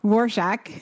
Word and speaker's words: Rorschach: Rorschach. Right? – Yeah. Rorschach. Yeah. – Right? – Yeah. Rorschach. – Okay. Because it's not spelled the way it's pronounced at all.